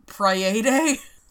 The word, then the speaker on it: Praed: P-R-A-E-D Street. – Praede? – Praed Street. I don't fucking know. – In most linguistic, like, rules, I know it would be Praede.